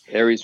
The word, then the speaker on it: Aeris: And it is, you spell it A-E-R-I-S. – Thank you. – Aries Winery, it's great.